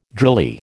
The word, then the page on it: drily: American English